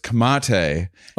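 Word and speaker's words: Comte: Someone pointed out to me that for a year and a half, I've been saying like one of the mainstays of our show. It's C-O-M-T-E.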